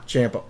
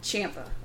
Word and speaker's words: Ciampa: Ciampa, whatever. You know I always say it wrong. – Ciampa.